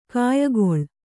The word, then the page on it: ಕಾಯಗೊಳ್: kāyagoḷ